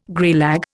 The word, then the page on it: greylag: Indian